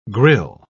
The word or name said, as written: grill